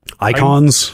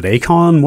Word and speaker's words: Eikons: Icons? Final Fantasy. – E-I-K-O-N-S. – Yeah. – I went into this wonder, is it Akon? What are we doing here? But no, it literally is just pronounced Icon.